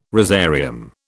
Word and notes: rosarium: British English

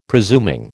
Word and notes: presuming: American English

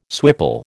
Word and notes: swipple: American English

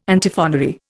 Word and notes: Antiphonary: Indian

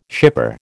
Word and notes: shipper: American English

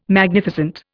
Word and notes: Magnificent: Indian

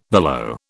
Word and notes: billow: British English

